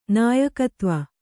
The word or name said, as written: ನಾಯಕತ್ವ